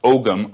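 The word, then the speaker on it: Ogham: But ancient Celts, traveling to the Americas 2,000 years ago, left their mark in stone in Oklahoma, in a writing system called Ohm, or often pronounced Ogam, O-G-A-M, and describing the rituals of an ancient sun-worshipping culture.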